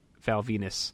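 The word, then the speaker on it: Val Venis: And on Backlash's actual heat, Val Venus, rhyming with penis, defeated mm-hmm.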